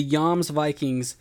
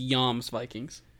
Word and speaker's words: Jomsvikings: I'm pretty sure it's pronounced Jomsvikings Vikings. – Well, dude, here's the thing. – I think a J is like a Y.